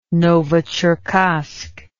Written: novocherkassk

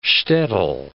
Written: shtetl

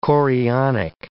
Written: chorionic

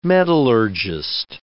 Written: metallurgist

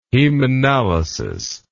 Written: hemanalysis

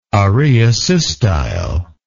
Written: araeosystyle

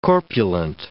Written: corpulent

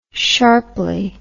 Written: sharply

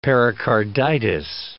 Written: pericarditis

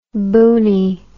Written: boonie

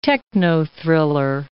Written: techno-thriller

